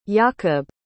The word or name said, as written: yakub